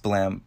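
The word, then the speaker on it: Blame!: It's pronounced Blam, but it's spelled Blame, and it has an exclamation point at the end.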